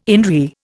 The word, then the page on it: in re: Indian